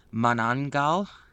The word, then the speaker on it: Manananggal: It is the Manananggal.